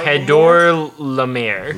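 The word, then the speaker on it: Chedorlaomer: How do you actually pronounce his name? – Uh, uh, it's Kedar Lamar? Kedar. – Kedor Lamir. Laumer.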